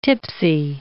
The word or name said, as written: tipsy